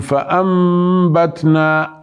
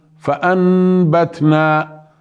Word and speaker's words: فأنبتنا: النون انقلبت إلى ميم، ليش؟ لأنه أتى بعدها حرف الباء، أصلها فأنبتنا فانقلبت النون فأنبتنا لتسهيل النطق، ليكون النطق أسهل، حتى يكون النطق أسهل نقول فأنبتنا، لا نقول فأنبتنا، وهذا يشبه الإخفاء الشفوي الذي شرحناه قبل قليل، إنما هذا يقال له الإقلاب.